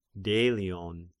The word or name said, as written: Delion